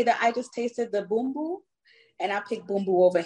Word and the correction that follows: Bumbu: Okay, the Bumbu. – Yeah, the Bumbu. I, I pronounced it wrong.